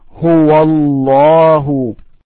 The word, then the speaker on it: Huwallahu: Huwallahu. – Huwallahu. Huwallahu. – Try to ignore the first letter in the next word, Allahu. So when you recite, you say Huwallahu, Huwallahu, without pronouncing the A.